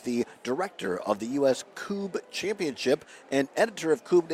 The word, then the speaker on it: Kubb: It looks like Cub. K-U-B-B is pronounced Coob.